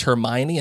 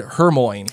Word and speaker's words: Hermione: Melissa asks, did anyone else mispronounce Hermione until the movies? For some reason, she said Hermoine.